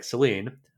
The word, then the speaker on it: Celine: Jeremy and Jordana agreed to pronounce her name like Celine, which is a French name similar to the Greek word for, any guesses? – The goddess of the moon, Selene.